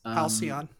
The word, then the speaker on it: Halcyon: Forgive me if I'm not saying that right. – Halcyon, that's correct.